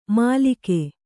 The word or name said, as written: ಮಾಲಿಕೆ